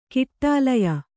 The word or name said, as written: ಕೌಟಿಲ್ಯ